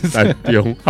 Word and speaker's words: 田中: 他说，可是问题是因为以前就发现到，如果你念田田中，大家会觉得你是去田里面做事，可是那是一个地名，对，它就变成另外一个发音，对对对，是田中，好好无聊啊！